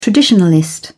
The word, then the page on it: traditionalist: British English